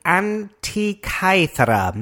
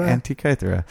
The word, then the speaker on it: Antikythera: The Antikythera mechanism. – I think that was actually pretty good. Actually, I think that is how it's pronounced. Say it again. – Antikythera.